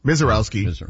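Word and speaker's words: Misiorowski: Best case scenario for MLB, the Brewers and the Dodgers and the NLCS, where you have a star player in Mazurowski. Is that how you pronounce his name? – Mazurowski. – Yeah.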